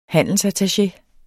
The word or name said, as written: handelsattaché